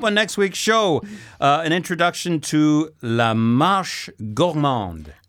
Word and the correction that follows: marché: Marché? – It's le marché. Yeah, accent aigu. Marché gourmand.